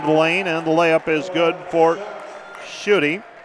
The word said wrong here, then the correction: Schutte: I beg your pardon, shot. Yeah, it helps if I check my pronunciation guide.